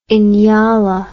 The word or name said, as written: inyala